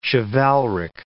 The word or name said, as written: chivalric